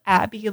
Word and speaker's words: Abbey: And my name is spelled Abby like the road, A-B-B-E-Y.